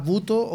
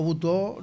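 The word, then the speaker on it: butoh: Cuéntame un poco, Paulina, ¿qué es la danza buto o butó? No sé si lo estoy pronunciando bien. Corrígeme si es necesario. – Bueno, igual de repente la pronuncian de distintas formas, así que está bien.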